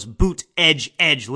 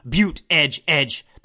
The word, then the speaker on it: Buttigieg: I guess it did help me remember his name when you see those boot edge edge, literally EDGE-EDGE, boot edge edge, because his name is not pronounceable, unrecognizable.